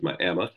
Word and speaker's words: Miami: If you're from Miami, you know to pronounce it as Miami.